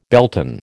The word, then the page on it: Beltane: American English